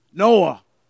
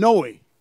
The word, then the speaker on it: Noah: Noah. The Southerners called him Noe. If you're from Arkansas, you called him Noe. Almost rhyming with joy or toy our boy.